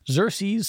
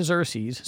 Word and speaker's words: Xerces: Montana kicked off its documentation efforts this year with Xerces Society Invertebrate Conservation leading the effort. Livingston-based Xerces, and I hope I'm pronouncing this right, it's X-E-R-C-E-S, so I'm going with that till someone tells me otherwise.